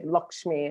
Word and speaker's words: Lakshmi: Lakshmi, I'm saying Lakshmi, but I think Lakshmi is more correct pronunciation.